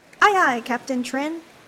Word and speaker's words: Trịnh: It's pronounced Trin.